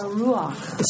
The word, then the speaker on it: ruach: I'm not sure I even say it right. – The ruach. – So there's no... Oh, there is a ch at the end.